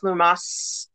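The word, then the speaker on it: Lughnasadh: I believe it's pronounced Lunasa.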